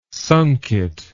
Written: sunket

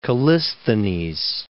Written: Callisthenes

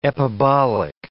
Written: epibolic

